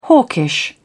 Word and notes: hawkish: British English

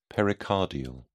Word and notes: pericardial: British English

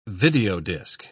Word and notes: videodisk: British English

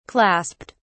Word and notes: clasped: British English

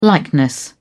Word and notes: likeness: British English

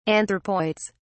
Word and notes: anthropoids: British English

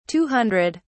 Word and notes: 200: British English

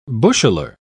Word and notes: busheler: British English

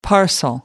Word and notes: parcel: American English

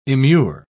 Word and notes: immure: British English

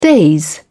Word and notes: daze: British English

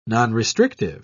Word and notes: nonrestrictive: British English

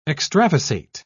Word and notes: extravasate: British English

